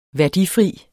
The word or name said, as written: værdifri